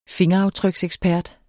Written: fingeraftryksekspert

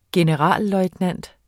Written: generalløjtnant

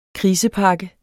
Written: krisepakke

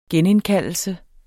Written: genindkaldelse

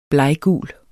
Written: bleggul

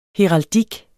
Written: heraldik

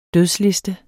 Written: dødsliste

